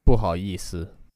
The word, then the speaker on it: 不好意思: 不好意思.